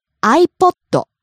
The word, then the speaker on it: iPod: iPod. – iPod. – iPod. iPod. – Rises on the second syllable. E falls on the last syllable.